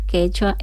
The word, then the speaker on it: quechua: Sí, sí, el quichua es de Ecuador y el quechua es de Perú.